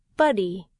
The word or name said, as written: buddy